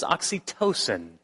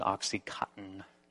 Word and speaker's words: oxytocin: Uh, the correct pronunciation of it is oxytocin and that is also called the cuddling hormone.